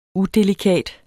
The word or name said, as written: udelikat